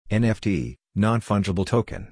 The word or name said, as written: NFT (Non-Fungible Token)